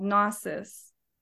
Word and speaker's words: gnosis: Now, where magic and alchemy differs and why it's the R18 version is it uses another step called gnosis, altered consciousness. G-N-O-S-I-S.